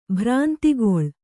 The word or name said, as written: ಭ್ರಾಂತಿಗೊಳ್